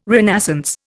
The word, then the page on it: renascence: Indian